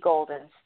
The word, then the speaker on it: Golden: H-U-B, and then another forward slash, and then my name, which is Stacey Golden. Stacey is spelled S-T-A-C-E-Y. Golden is G-O-L-D-E-N. So hopefully you caught that. Legal Shield, L-E-G-A-L, shield, like a shield, .com/hub/ Stacey Golden.